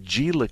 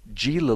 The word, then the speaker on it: Gila: Gila looks like Gila.